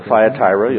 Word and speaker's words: Thyatira: Now the message to the church at Thyatira, or Thyatira, you'll hear it pronounced both ways.